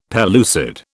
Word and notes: pellucid: British English

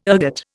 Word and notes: ergot: Indian

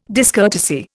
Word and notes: discourtesy: Indian

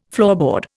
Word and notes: floorboard: Indian

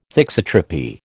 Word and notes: thixotropy: American English

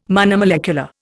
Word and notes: monomolecular: Indian

